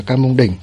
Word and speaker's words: Cam Mông Đình: Ở đây thì dịch giả của chúng ta lúc thì viết là Cam Mông Điền, lúc thì viết là Cam Mông Đình. Đâm ra là để thống nhất thì mình sẽ dịch là Cam Mông Đình, mình sẽ đọc là Cam Mông Đình nhé.